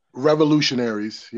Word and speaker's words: ReviLLutionaries: So it's how do you say it exactly? – Revolutionary. – It's Revolutionaries, yeah.